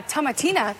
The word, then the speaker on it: Tomatina: La Tomatina, did I say that right? Tomatina? – Yeah.